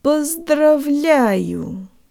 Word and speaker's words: поздравляю: The O is not stressed, and here it sounds like a schwa. Bo. P. Let's put it all together. Jo tank lips. Поздравляю.